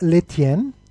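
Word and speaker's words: Lestienne: Ja, Dominik ist da, spielt gegen Constant Letienne. Das S spricht man nicht aus, bitte.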